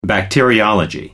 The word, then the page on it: bacteriology: American English